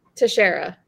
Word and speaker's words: Teixeira: It's one of those names I can never Teixeira. – Teixeira, yeah.